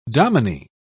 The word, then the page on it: dominie: British English